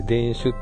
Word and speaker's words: 田酒: 田酒、田んぼ、田の酒って書いて田酒って読むんですけどね。 – うん。 – うん。 – 田酒って何ですか。 – 田酒っていう銘柄です。 – あ、銘柄なんだ。 – うん。ブランド名というか銘柄の名前で、うん、ですよね。 – 確か田酒、山口だったか、どっちだったか、ちょっと忘れちゃったけど。